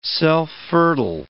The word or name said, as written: self-fertile